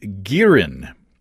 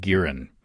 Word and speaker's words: Girin: I did some searching online to see if anybody's making makgeolli commercially in the U.S., and I found Girin. I believe that's the way you pronounce it, G-I-R-I-N, a Korean steakhouse in Seattle.